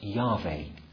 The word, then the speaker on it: Yahweh: And this name is commonly pronounced Yahweh, although it's often mispronounced, for example, by the Jehovah's Witnesses as Jehovah.